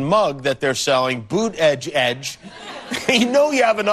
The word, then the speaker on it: Buttigieg: All right, so you pronounce it boot, B-O-O-T, edge, edge. – Buttigieg.